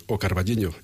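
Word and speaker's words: O Carballiño: Eh, como sabéis, eh, como has dicho al principio, Almudena, soy de un pueblo de Ocarvallino es la pronunciación correcta,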